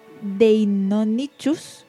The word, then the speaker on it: Deinonychus: A ver si lo he dicho bien. Deinonychus. Ahora sí.